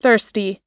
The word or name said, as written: thirsty